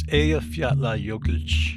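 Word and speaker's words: Eyjafjallajökull: Am I pronouncing that correctly? – Uh, nearly. It's Ayafyatla Yokelch.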